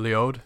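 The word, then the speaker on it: Léaud: Leod.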